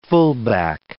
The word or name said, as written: fullback